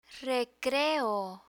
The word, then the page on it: recreo: Spanish